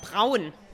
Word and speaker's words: Braun: So we really pronounce the R. Like Braun. – Okay. – Can you do that? Braun. – Braun. No, I created a new syllable. – No, it's perfect. – Okay. – Braun. Lots of people have difficulties with the German R.